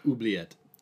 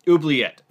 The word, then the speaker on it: Oubliette: If you want to forego those t- those two rooms, you can go to the other side, and that is Obli- Obulete. I'm saying, I hope I say that right. Oubliette. Oubliette. Thank you.